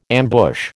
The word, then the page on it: ambush: American English